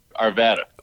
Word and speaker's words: Arvada: Nobody can say it right. – What's, correct, what's the correct pronunciation? – Arvada. – Arvada. – Arvada. Oh, we were right. Okay, we're close.